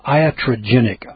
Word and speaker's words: iatrogenic: Looking here in the article, iatrogenic, I-A-T-R-O-G-E-N-I-C, iatrogenic, odd term.